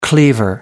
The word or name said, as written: cleaver